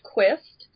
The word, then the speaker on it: Quist: Tell us all about the Quest app. – Sure. Well, first, a small correction. It's pronounced Quist because it is a mashup of the words queer history. So it's like history.